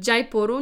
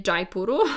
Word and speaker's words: Jaipur: i stamtąd do Jaipuru, nie Jaipuru, chociaż no dobra, niektórzy tak mówią i też myślę, że z tego co pamiętam jest spór pomiędzy wydziałami w różnych miastach w Polsce ogólnie i są zwolennicy właśnie takiej wymowy dż, ale ona nie jest po prostu poprawna najzwyczajniej w świecie ze względu na to,